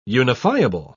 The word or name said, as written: unifiable